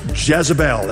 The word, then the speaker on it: Jezebel: Starts with J, rhymes with Isabel. Jezebel, that's right.